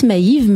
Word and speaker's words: Maiv: Voilà, donc Francis Maïve, Maïve ça s'écrit M-A-I-V.